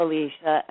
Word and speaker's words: Alysia: It was in the 70s, and they liked the sound of Alicia, and they liked the look of Alicia, and they put them together. So it's you pronounced it perfectly from the page. – Uh-huh. So say it one more time for myself and my audience. – Alicia. – Alicia. – Alicia. – Did I say it right? – Alicia, yeah.